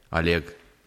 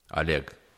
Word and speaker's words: Oleg: Oleg. Because like we, like pretty much everybody like pronounce like a first O like A, Oleg.